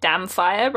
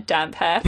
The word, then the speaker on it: Damphair: For, like, the first two times I read this book, I did pronounce it Damfire rather than Damphair for some reason.